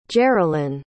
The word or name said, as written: jerolin